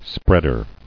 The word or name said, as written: Spreader